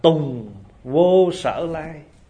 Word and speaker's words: tùng vô sở lai: Cho nên cái chữ này phải đọc là tùng vô sở lai, diệt vô sở khứ.